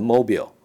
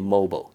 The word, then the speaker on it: mobile: 那个 MBB 用大写，第一个是 mobile，哦，有人会念成 mobile，哦，M O B I L E，应该是念 mobile，你相信我，啊、哦。